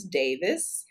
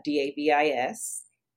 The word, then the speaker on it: Davis: Last name is Davis. That is Davis.